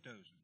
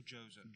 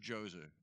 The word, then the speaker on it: Djoser: How did you say his name? – Pharaoh Dozier. – Dozier. – Or Djoser. – Djoser. – D-G-S-O-R. – Right, right. Djoser. Something like that.